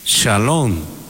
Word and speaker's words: Shalom: Por eso el saludo después de la resurrección es Shalom. Los judíos lo pronuncian Shalom, que significa la paz esté contigo.